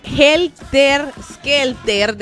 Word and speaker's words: Helter Skelter: Oscar Velázquez nos había dicho Helter Shelter de... ¡Ay! Siempre lo digo mal. Helter Skelter de los Beatles, esa canción.